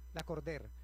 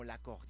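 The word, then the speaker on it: Lacordaire: La Cordère, pero como en francés, como La Cordère, sí. Una cosa así. – Es que es un nombre francés, se escribe La Cordaire, pero se pronuncia La Cordère.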